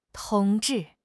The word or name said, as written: 同志